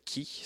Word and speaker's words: Khi: Donc, je vais débuter avec un artiste qui vient de l'Estonie, qui s'appelle Ki. Ça s'écrit Khi.